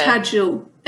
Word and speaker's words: Tadzio: Tadzio. I mean, so the pronunciation. – Tadzio. – Yeah, because he's Polish. So the address, like if you address him directly, you would say Tadzio with the U sound, which Tadzio.